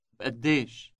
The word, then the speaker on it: بقديش: Note the B sound at the beginning. – بقديش.